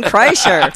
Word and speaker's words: Kreischer: They were on This Life with Dr. Drew and Bob Forrest, and it's Bert and Leanne Kreshner. And that's how you say it, right? – Kreshner. Doesn't matter. – What? Kreischer? Oh, yeah. – Okay. It's Bert and Leanne Kreischer.